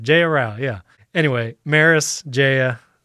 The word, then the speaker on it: Meross: It's spelled M-E-R-O-S-S, but it's pronounced Maris.